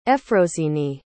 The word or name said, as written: efrosini